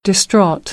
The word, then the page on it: distraught: American English; ɑː - ɔː